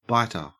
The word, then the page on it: biter: Australian English